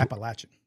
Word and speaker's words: Appalachian: I said Appalachian State. I don't know. – It's Appalachian.